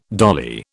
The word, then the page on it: dolly: British English